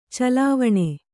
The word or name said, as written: ಚೆಲುವಾನ್